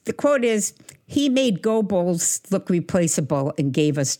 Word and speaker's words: Goebbels: For one, I think it's pronounced Goebbels, but...